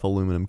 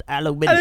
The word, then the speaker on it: aluminium: I don't know if aluminum can chip. – Aluminium. It's pronounced aluminium.